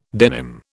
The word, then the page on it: denim: British English